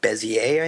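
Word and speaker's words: Bézier: I actually programmed in C++ what I always called, and everyone can correct me if I'm wrong, but like Bezier, I think. – That's probably much closer to the correct pronunciation.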